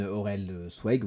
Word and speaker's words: Aurelsweg: bah moi je m'appelle donc Aurélien, Aurel, petit diminutif de la chaîne Aurel Swag ou Zweig, ça dépend comment, comment vous le prononcez.